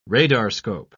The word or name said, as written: radarscope